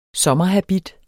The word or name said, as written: sommerhabit